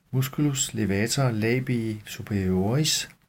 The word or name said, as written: m. levator labii superioris